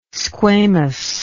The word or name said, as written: squamous